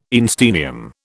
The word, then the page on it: einsteinium: British English